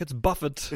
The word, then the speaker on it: Buffett: People go buffet. He's like, "It's Buffett. – It's Buffett. Yeah.